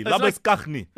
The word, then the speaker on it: Labuschagne: Labuschagne.